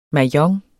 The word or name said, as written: MAHJONG